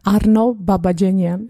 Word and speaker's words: Babajanyan: Arno Babajanyan.